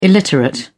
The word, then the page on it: illiterate: British English